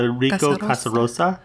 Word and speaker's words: Enrico Casarosa: I want to say that's how you say his name. Enrico Casarosa.